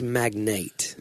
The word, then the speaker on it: magnate: But it's magnate. – No, it's not. I look at the pronunciation in the text that I sent you. It can be pronounced magnet.